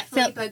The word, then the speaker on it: Philippa: Philippa. Am I saying it right? – Yeah, Philippa Gregory.